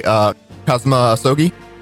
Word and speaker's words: Kazuma Asogi: Kazuma Asogi? – Yeah, you got it.